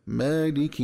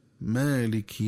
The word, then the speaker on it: مالك: فعند قراءة الآية الرابعة وهي قوله تعالى مالك يوم الدين، ينبغي التنبه للنطق الصحيح لكلمة مالك عند الوصل، فتقرأ بكسرة خالصة واضحة هكذا مالك يوم الدين. ومن الخطأ قراءتها في الوصل بالتسكين هكذا مالك يوم الدين.